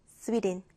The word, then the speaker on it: Sweden: 우리나라 말로는 스웨덴이지만 영어로 발음할 때는 스위덴입니다. 한번 따라해 보시겠어요? 스위덴.